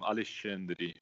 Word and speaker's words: Alexandre: I'm Alexandre," because in Brazil the X has the sound of like sh.